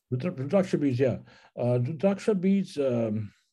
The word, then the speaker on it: Rudraksha: And you also talk about Rudraksha beads. Did I say that right? – Absolutely. Those are my favorite, actually. Rudraksha beads.